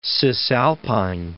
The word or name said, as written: Cisalpine Gaul